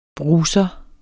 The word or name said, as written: BRUSERE